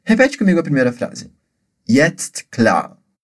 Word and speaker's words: jetzt: Lembra que na pronúncia de jetzt tem esse som de tst, que é como se a gente falasse TST tudo junto, tá ok?